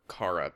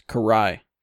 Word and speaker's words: Karai: I always just want to call her Kara, but I know that's not right. – It's Karai.